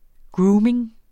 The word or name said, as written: grooming